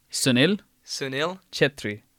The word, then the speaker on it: Sunil Chhetri: So, yeah, Ronaldo is first for Portugal, second is Messi for Argentina, and third is Sunil Chhetri for India. Players. – Right, can you rewind? Can you say his name slowly? – Sunil? Chhetri.